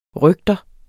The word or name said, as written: røgter